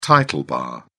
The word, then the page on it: title bar: British English